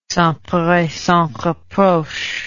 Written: sans peur et sans reproche

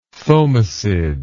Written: thomisid

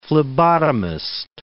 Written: phlebotomist

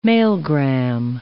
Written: Mailgram

